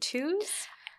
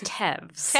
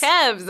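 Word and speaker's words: Toews: Miriam – is it Tews? – I believe it's Tevs. – Tevs, okay.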